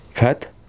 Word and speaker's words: PHAT: Fat? – PHAT. – PHAT. – Ale vyslovil jsi to správně.